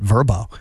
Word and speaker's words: Vrbo: I've called it Vrbo my entire life. – Okay. – I went into an air check meeting with my boss, and he's like, you know, they changed it. You got to pronounce it Vrbo. And yes. I was like, said who? Anyway, there's a commercial with it, and they've changed the name to Vrbo. Anyway, yeah.